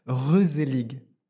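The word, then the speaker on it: gezellig: Mon mot de cette année, c'est Geselig, ça s'écrit, et ça se prononce Reuselig.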